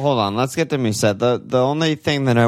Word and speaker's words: Mousset: I'd rather have Mousset, not close, for a million less. – Mousset, I don't think, dude, that's, don't say that. Don't pronounce that. The T is French, dude.